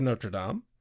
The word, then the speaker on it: Notre Dame: Cut to the college the next day, where Tara, Willow, and Buffy are walking and discussing The Hunchback of Notre Dame.